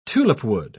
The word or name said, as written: tulipwood